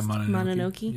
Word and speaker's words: Mononoke: And now, supposedly, that no-cuts policy was really thrown into sharp relief when Miramax suggested editing Princess Mononoke, I think is how it's pronounced. – Yeah, Mononoke. Yeah. – Mononoke.